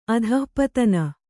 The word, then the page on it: ಅಧಃಪತನ: adhahpatana